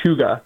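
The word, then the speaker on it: Huega: We wanted to make the perfect hat, like the perfect silhouette, and we came up with a cool name, and it's pronounced Huga, and it's Huga House.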